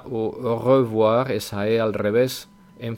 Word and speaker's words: au revoir: Ahí os he puesto que se pronuncia de dos maneras. Depende un poco de si estamos hablando rápido o si hablamos despacio y vocalizamos bien. En este último caso, que es el que vosotros deberíais empezar aprendiendo, es en la primera pronunciación que pongo, que es au revoir, au revoir. Y si hablamos rápido, au revoir.